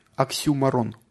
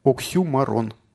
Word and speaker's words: оксюморон: Есть такое слово греческого происхождения, оксюморон, оксюморон, оксюморон на ю ударение.